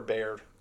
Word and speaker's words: Beard: So, you know, is it Beard or Baird? Beard, right? – Chris Beard.